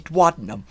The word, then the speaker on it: duodenum: I just call it duodenum. – That's how you say it, duodenum. – Oh, cool. – Oh, well, there you go. That's how that word is said.